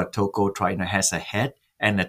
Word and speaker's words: tocotrienol: So you did mention this compound, this tocotrienol.